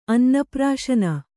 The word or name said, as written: ಅನ್ನಪ್ರಾಶನ